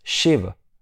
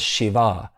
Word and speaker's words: Shiv: Now, when we look at the word Shiv, the actual word is Shiv. It's a small A, but a lot of the times you'll also see Shiv pronounced as Shiva.